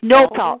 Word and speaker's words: Nopal: That was for diabetic, and that's Noble. – It's Nopal. It's spelled N-O-P-A-L, Nopal.